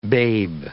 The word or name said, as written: babe